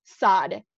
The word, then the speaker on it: Saad: And the second one is Layla Saad. S-O-D is how you pronounce it, like Saad, but it's spelled S-A-A-D.